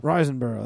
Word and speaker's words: Riseborough: Who's this? Andrea Riseborough. – Riseborough. That's how you pronounce...